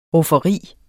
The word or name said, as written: rufferi